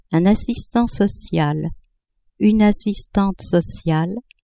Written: assistant social-assistante sociale

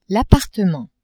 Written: appartement

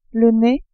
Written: nez

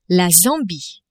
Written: Zambie

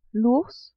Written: ours

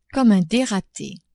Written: comme un derate

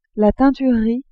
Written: teinturerie

